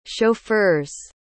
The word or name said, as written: chauffeurs